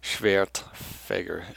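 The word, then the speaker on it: Schwerdfager: Schwertfeger, S-C-H-W-E-R-D. W is a V in German pronunciation, F-A-G-E-R, Schwertfeger.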